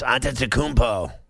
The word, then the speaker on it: Antetokounmpo: Yes, Antetokounmpo.